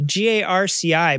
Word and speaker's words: Garci: It's uh, G A R C I, but they pronounce it Garci Rodriguez de Montalvo.